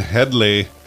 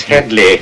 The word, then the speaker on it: Headley: Glenn Headley. – It's Headley. It's Headley.